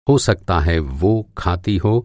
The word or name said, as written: सकना